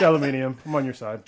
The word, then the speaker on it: aluminium: That and why do you pronounce aluminum weird? I guess maybe we announce it. Maybe we say it weird. I know it's aluminium. – Say it weird. – Holy aluminium.